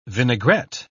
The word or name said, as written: vinaigrette